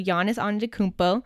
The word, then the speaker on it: Giannis Antetokounmpo: So Giannis Antetokounmpo.